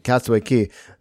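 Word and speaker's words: Castaway Cay: Isn't it Key? – It's spelled K, but it's pronounced Key. – Got it. Castaway Key.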